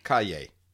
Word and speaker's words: Calle: Calle. The two L's is pronounced as a Y. De la Calle.